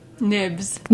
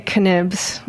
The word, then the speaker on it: Knibbs: Nibs. – Nibs. I was going to say Knibs. – No, the K is silent, and I guess one of the Bs, but thanks for having me.